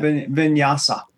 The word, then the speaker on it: Vinyasa: Vinyasa.